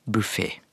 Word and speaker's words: buffé: og dessuten så er det meir òg i samsvar med uttalen, for det skal jo uttales buffé.